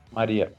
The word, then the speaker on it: Maria: Então, ele provavelmente iria abusar sexualmente da Mara Bakalova, né? É esse o nome dela? Mara Bakalova ou eu tô yeah. falando errado? Maria. Maria, desculpa. Enfim, vexame, ele devia ter sido preso, para falar a verdade.